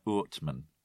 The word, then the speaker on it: Oortman: My Dutch pronunciation is probably awful there. Nadine Ackerman, if you're listening to this, you should correct my pronunciation there. It's OOrtman.